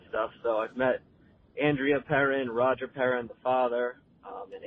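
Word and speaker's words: Perron: and you kept pronouncing it Perone. It's actually pronounced Perrin, and every time I heard you do it, I wanted to rip my ears out.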